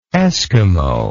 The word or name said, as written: eskimoid